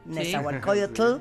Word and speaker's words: Nezahualcóyotl: Nezahualcóyotl ¿Lo dije bien? Nezahualcóyotl.